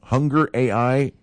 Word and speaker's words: Hungr.ai: It's, they've launched Hungry AI. Hunger AI, yeah, that's what it's called.